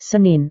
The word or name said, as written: senin